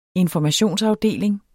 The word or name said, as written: informationsafdeling